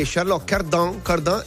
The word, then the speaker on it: Charlotte Cardin: E lei mi ha detto che Charlotte Cardin è l'esatta pronunciation.